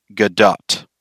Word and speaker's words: Gadot: Her last name doesn't rhyme with the word doe. The T on her last name isn't silent. It's pronounced Gadot.